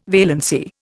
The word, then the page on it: valency: Indian